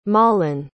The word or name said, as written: malin